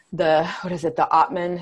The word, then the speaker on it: Atman: I think in, you know, Hinduism, there is the Atman. Is that correct? Am I saying that right, Gurbani? – Yeah, that's correct.